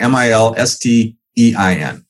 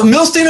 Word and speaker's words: Milstein: No, Milstein is fine. It's it's either way. It works. My mom said Milstein. My dad say, said Milstein.